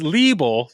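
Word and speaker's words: Lieble: So apparently I was just talking to myself, going, Liebel. But it's Liebel.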